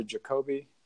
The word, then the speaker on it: Jacobi: Jung, Yolanda Jacobi, J-A-C-O-B-I, Jacobi is how they pronounce it, actually, J-A-C-O-B-I.